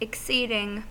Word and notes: exceeding: American English

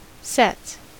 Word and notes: sets: American English

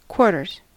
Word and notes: quarters: American English

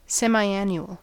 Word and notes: semiannual: American English

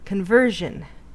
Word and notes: conversion: American English

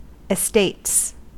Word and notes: estates: American English

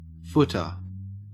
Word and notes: footer: Australian English

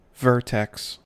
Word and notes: vertex: American English

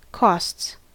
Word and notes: costs: American English

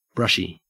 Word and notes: brushy: Australian English

